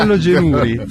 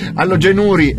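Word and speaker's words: alogenuri: Ma non era allogenuri. – Allogenuri, è vero, hai ragione, l'accento è sulla U.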